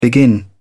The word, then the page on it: begin: British English